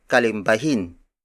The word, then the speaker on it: kalimbahin: Its Tagalog counterpart is kalimbahin.